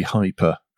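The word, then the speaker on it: HIPER: That hyper bit, by the way, it's HIPERCAR, and it's high performance carbon reduction. – Because I was pronouncing it hipper. – Hipper. No, it seems to be, it does seem to be hyper, as in high performance.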